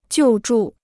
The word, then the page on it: 救助: jiù zhù